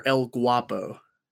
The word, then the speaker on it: ElGuapo: It is Tyler ElGuapo.